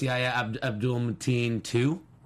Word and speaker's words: Yahya Abdul-Mateen II: Yahya Abdul-Mateen II. – Nailed it. – I hope I did. – I believe it's pronounced Yahya Abdul-Mateen II.